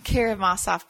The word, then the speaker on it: Karamazov: Karamazov Brothers.